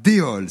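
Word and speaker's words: Déols: Déol, d'abord, je précise que le nom de la commune s'écrit D-E-O-L-S, mais on ne prononce pas le S à la fin. Déol, c'est une ville de 7800 habitants qui jouxte Châteauroux, dans le département de l'Indre.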